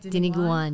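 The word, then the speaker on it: Dinuguan: Diniguan. Am I saying that right? – Diniguan, yes. Yes.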